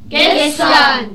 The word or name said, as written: gestern